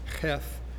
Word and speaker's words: heth: Your Bible may say something about, it looks like heth, it's pronounced heth in the Hebrew.